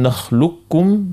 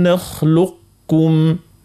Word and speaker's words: نخلقكم: الوجه الثاني يتسمى إدغام نقص، هنا باش تقعد كالصيفة متاع القاف اللي عنده صيفة الاستعلاء وصيفة التفخيم حرف القاف، نقوله ألم نخلق ننطقها القاف، يعني ألم نخلقكم.